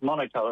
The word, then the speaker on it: Monaco: And he said there is no R in Monaco. So you always pronounce it Monaco, apparently.